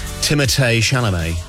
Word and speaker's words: Timothée Chalamet: I'm watching, have you seen Marty Supreme, the film with Timothée Chalamet, and that's how you say it apparently.